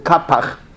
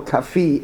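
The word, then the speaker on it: Qafih: In the rabbinic world, it's called Kapach, but I think Kaffa or Kafi is closer to the Arabic, authentic Arabic name that he has.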